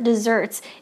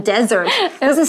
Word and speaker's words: dessert: Okay, for instance, we can say one of Nancy's favorite desserts is chocolate cake with a scoop of ice cream on the side. – Yum. Okay. 所以接着我们来看 food 食物会可以怎么分类哦？你可以在上面写像是 main course Dessert. 记得发音不能讲成 desert，那